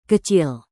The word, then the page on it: kecil: Indonesian